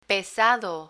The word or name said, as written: pesado